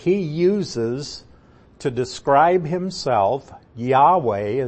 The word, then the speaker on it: Yahweh: And you would say it, Yahweh.